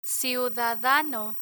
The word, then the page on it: ciudadano: spanish